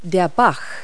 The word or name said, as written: stream